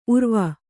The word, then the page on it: ಉರುವು: kannada